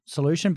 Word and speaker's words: Solushin: So your product's called Solution, but it's spelled S-O-L-U-S-H-I-N.